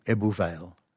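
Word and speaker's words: Ebbw Vale: C-W-M, Cwm Rhondda is the Rhondda Valley, all right? And Ebw Vale, E-W-E-B-B-W, you pronounce it ooh, Ebw Vale in Welsh. So W in Welsh is a vowel.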